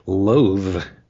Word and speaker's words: loathe: Loathe, which rhymes with clothe, is a common verb, meaning dislike intensely.